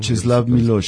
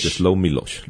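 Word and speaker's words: Czesław Miłosz: Czeslaw Milosz. – Czeslaw Milosz.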